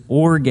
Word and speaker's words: orge: The Greek word is orge, O-R-G-E, which I remember because it reminds me of ogre, even though it's spelled a little bit differently, right?